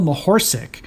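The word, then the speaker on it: Mohorcic: All right, the name that I keep botching, Dale Mahorsic is how you say it. Dale Mahorsic, nicknamed the horse, as the back of the card says.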